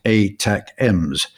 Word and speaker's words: ATACMS: He says it's pronounced ATACMS.